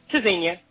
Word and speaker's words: Tzynya: Tzynya.